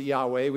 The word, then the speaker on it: Yahweh: We pronounce it Yahweh. We don't know how it was actually pronounced because the consonants weren't pointed in the scripture, so we don't know the vowels that were used.